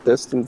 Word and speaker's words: Destin: Destin, Florida. Did I say that right? Destin, Florida.